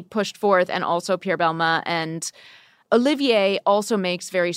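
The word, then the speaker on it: Balmain: And we actually had a really great conversation with Olivier Rostang, who is the creative director of Belmain. Was that pronunciation good? – Perfect. Belmain is an old school heritage French brand started by Pierre Belmain right after the war.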